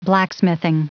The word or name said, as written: blacksmithing